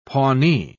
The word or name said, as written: pawnee